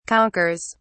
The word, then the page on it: conquers: British English